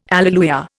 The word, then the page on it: alleluia: Indian